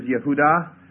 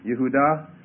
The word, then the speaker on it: Yehudah: And the Hebrew Judah means praise Yah, and literally the Hebrew word is Yehudah. Yehudah, and of course we pronounce it Judah in the transliteration or the way in the anglicized pronunciation. Y-E-H-U-D-A-H, Yehudah is the Hebrew form.